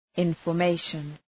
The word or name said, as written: information